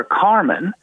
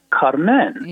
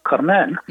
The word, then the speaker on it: Carmen: I said, "Look, this is a problem here," and talking about Carmen, that we call her Carmen. But in French, she is called Carmen. – Yeah. – And Bizet was French, so the accent is on the final syllable, Carmen.